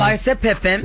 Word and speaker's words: Larsa Pippen: Larsa Pippen.